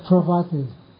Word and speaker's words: Parvati: Pravati. – P-R-A-V-A-T-I. – No. – P-A-R. – P-A-R-V-A-T-I.